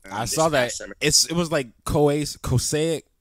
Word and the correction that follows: Kosovo: Yeah, I don't know how. Kosic. – No, you're good. Kosovo. Kosovo. – Kosovo. See, yeah.